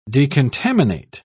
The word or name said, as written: decontaminate